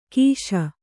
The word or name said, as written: ಕಶಿ